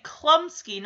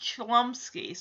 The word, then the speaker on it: Chlumsky: And he does, when he, in the trailer, it does say Klumsky, not Chlumsky. So I'm guessing the H is silent, but...